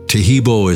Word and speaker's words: Tahibo: Tahibo is spelled T-A-H-I-B-O.